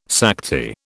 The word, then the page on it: Sakti: British English